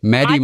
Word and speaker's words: Mady: Und Maddie wird geschrieben M-A-D-Y. – Okay. – Maddie Morrison. So. Und, okay. – Die kann Achtung. – Man ja gar nicht deutsch aussprechen. Da muss man sagen. – Maddie Morrison.